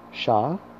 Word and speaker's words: Shalom: Repeat after me, Shalom. You'll spell this in English as S-H-A-L-O-M. Again, that's S-H-A-L-O-M.